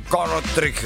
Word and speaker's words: Kortrijk: Kortrijk. Esto es bastante raro porque obviamente sucedió en Bélgica, por eso lo pronuncio así.